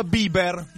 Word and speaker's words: Bieber: Arriva l'errata corrige, si pronuncia Bieber.